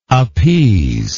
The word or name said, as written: appeasingly